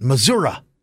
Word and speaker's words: Missouri: That's how the locals say it, Missouri.